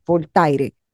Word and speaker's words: Voltaire: Yo le digo tal cual se escribe, Voltaire.